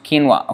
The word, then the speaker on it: quinoa: So quinoa, pronounced quinoa. Okay, thank you. Quinoa. Okay, that's how it's pronounced.